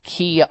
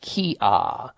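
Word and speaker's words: Qi'a: It's called Kia, and it's spelled Q-I apostrophe A, but it's pronounced Kia.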